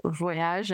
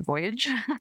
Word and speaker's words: Voyage: I'm assuming it's pronounced Voyage and not Voyage. – Yeah, French.